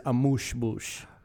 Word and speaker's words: amuse-bouche: But I believe the word is amuse-bouche.